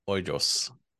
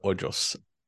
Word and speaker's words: Hoyos: My last name is really easy to mess up. It's pronounced Hoyos. – Hoyos.